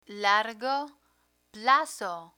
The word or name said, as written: largo plazo